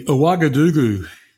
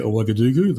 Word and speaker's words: Ouagadougou: Ouagadougou.